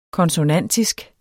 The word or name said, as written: konsonantisk